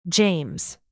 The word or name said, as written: james